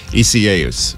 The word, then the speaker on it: Isaias: And then after time passed, everybody was saying it Isaias, which is the Spanish pronunciation.